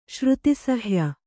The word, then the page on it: ಶ್ರುತಿಸಹ್ಯ: Kannada